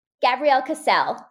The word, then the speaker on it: Gabrielle Cassell: Gabrielle Cassell. So it kind of rhymes.